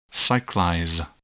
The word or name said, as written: cyclize